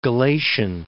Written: Galatian

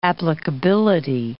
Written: applicability

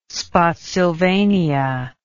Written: Spotsylvania

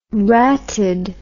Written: ratted

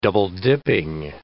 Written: double-dipping

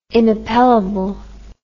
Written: inappellable

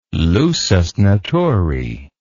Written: lusus naturae

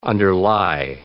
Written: underlie

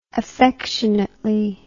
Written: affectionately

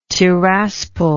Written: Tiraspol

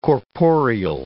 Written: corporeal

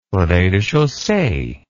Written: rez de chaussee

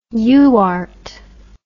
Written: UART

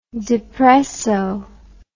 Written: depresso

